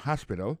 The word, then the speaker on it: hospital: L'hôpital c'est comme ça on dit ça en anglais, hospital. Alors accent tombé sur premier syllabe là, il y a trois syllabes. She likes to visit hospital. Ensuite, elle a écrit H-O-S-P-I-T-A-L, hospital, l'hôpital.